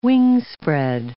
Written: wingspread

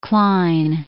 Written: Klein

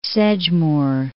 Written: Sedgemoor

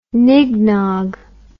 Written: nignog

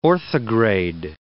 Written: orthograde

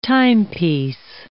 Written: timepiece

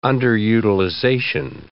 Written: underutilization

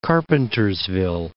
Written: Carpentersville

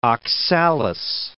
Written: oxalis

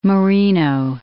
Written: Marino

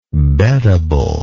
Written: beddable

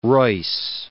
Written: Royce

